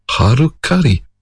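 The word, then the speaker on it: Halcali: Well, it actually ends up getting pronounced as Harukari, which sounds awfully like Harikari, a particular method of suicide in order to regain one's honour.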